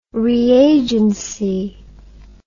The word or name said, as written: reagency